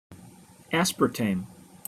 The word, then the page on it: aspartame: American English